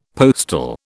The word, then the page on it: postil: British English